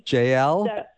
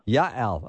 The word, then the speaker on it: Yael: JL? – JL, yes. – No, with a yes sound, like a Y. – Okay, say it correctly then for me. – Yael.